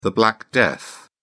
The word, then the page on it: black death: British English